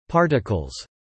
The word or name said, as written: particles